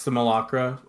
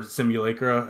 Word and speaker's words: Simulacra: Uh, I picked uh, Wax Simulacra or Simulacra, as you might pronounce it.